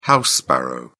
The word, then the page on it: house sparrow: British English